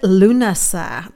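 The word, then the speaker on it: Lughnasa: So let's take a look at Lunasa. If you're wondering how to spell it, because it's a Celtic spelling, Irish spelling, it's L-U-G-H-N, Lu, N-A-S-A.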